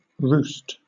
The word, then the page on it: roost: British English